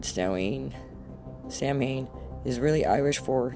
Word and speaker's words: Samhain: Pronounced Samhain. This is what this says. Most Wiccans refer to Halloween as Samhain, pronounced Samhain